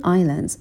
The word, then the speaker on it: island: And are distributed like tiny islands و پخش شده اند مثل جزایر کوچک. Island اسش تلفظ نمیشه.